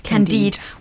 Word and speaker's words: Candide: Yes, Candide,